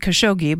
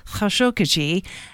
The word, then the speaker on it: Khashoggi: And here in the U.S., a lot of times we pronounce it Khashoggi, but it's Khashoggi.